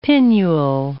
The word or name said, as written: pinnule